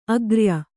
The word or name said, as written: ಅಗ್ರಿಯ